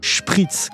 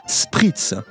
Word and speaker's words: Spritz: se prononcent avec une chointante, Spritz, comme dans les mots Schluck ou Schlapp. Schluck désignant une gorgée, Schlapp des chaussons, donc on boit un Spritz. En français, la graphie SP se prononce avec une sifflante, à témoin la prononciation des mots spéologie, spécificité. Il est donc légitime, quand on est français comme moi, de dire Spritz.